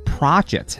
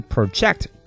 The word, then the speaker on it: project: 它的读音是 project，啊，注意它是一个名词，做动词我们刚刚讲过啊，名前动后啊，读成 project，它作为动词表示投射的意思。